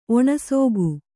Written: ಒಣಸೋಗು

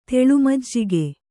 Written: ತೆಳುಮಜ್ಜಿಗೆ

